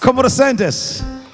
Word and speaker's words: ¿Cómo te sientes?: Como te sientes? – Good.